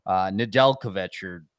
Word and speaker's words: Nedeljkovic: Nedeljkovic. – It is. Shit. You got it. You got it right.